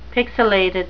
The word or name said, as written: pixilated